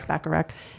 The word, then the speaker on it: Bacharach: Burt Bacharach, how do you say his name? – Bacharach, and Hal David.